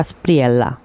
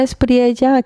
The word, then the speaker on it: Espriella: En realidad sería Abelardo de la Espriella. Pero como estamos en Latinoamérica, donde la doble, doble L, la Y, se pronuncia como Y prácticamente, entonces de la Espriella. De la Espriella,